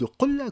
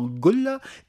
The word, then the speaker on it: qolla: On va utiliser le terme de qolla, qu'on peut prononcer également Golla, et ce mot générique représente donc la jarre.